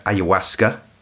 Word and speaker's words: ayahuasca: Some people say ayahuasca. – Ayahuasca. – I think they're wrong.